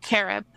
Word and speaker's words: Carob: Carob. – Carob, okay. – Uh-huh. Just like the health food, the imitation chocolate.